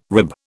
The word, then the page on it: rib: British English